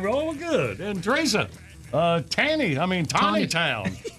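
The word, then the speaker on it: Taneytown: Tanny Town, Maryland. Is that you? Where you are, Teresa? – You know, Jackie is sitting right behind you. You could just ask. – Yeah. It's pronounced Tawny Town. Tawny Town.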